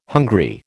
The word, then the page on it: hungry: American English